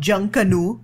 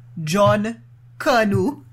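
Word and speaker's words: Junkanoo: So not Junk Canoe, John Canoe.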